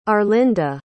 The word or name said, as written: arlinda